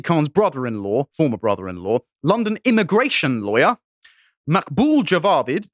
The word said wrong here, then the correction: Javed: I can't pronounce this, sorry, Javed was married to Sadiq Khan's sister until 2011.